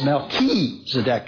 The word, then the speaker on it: Melchizedek: It no longer has got the hard sound. It's got the K sound. So it's Melchizedek, as actually pronounced in Hebrew.